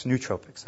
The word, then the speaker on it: nootropics: Nootropics, that's how you pronounce it.